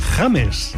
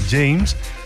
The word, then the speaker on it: James: Como ese pez que nada por las aguas de Japón, el Tosanoides Obama, también tenemos, volviendo a Colombia, el coral Paragorgia James, que supongo que habrá que pronunciar así y no James, ya que fue bautizado en honor al famoso futbolista.